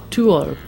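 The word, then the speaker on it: To Øl: There was Mikkel from McKellar and his brother Jeppe from Evil Twin, as well as Mikkel's student Thor from Tuol. – I'm impressed with that pronunciation of Tuol.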